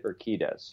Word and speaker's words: Urquidez: Do you pronounce his last name Urquidez, or how do you say Benny's last name? – I've always said it Urquidez.